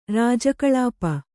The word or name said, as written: ರಾಜಕಳಾಪ